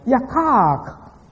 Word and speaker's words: yakach: Now, the expression "let us reason together" is translated from a Hebrew word, yakak. Yakak. That's spelled Y-A-K-A-C-H. That C-H is kai in the Hebrew.